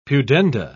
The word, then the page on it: pudenda: British English